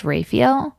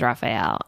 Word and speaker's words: Raphael: It's doubtful based on my appreciation for math and knowledge of it, but it's weird because my last name is actually should be pronounced Raphael. – Mm-hmm. – And when I was in high school, but everybody says Raphael. And so at this point, I've accepted it as truth.